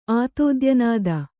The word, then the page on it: ಆತೋದ್ಯನಾದ: Kannada